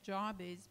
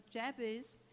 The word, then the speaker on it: Jabez: And good that Rachel was able to tell me how to pronounce the name, because I was thinking, as a South African, you might say Jabez, but the name is associated with pain, so Jab might be more appropriate. So Jabez was more honorable than his brothers.